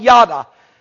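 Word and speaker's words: yada (ידע): The word here for know, K-N-O-W, the word for know your God, is the Hebrew word in the Old Testament called yada. I believe you spell it Y-A-D-A.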